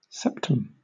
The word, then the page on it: septum: British English